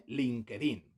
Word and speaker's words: LinkedIn: LinkedIn.